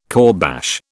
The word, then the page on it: kourbash: British English